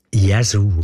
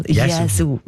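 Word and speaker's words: Yazoo: Ich habe mich immer gefragt, ob ich das richtig ausspreche oder nicht. «Jesu». – Jesu. – Gell? Jesu. Ja. Das ist richtig.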